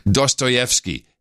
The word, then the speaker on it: Dostoevsky: I believe it's pronounced Dostoy- Dostoevsky. Yes.